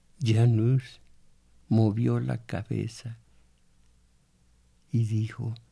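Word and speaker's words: Jan Hus: Janus, que así se pronuncia Juan de Hus,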